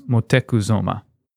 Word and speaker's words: Moctezuma: They brought it to Moctezuma, so that's Montezuma, but the way you pronounced his name was Moctezuma.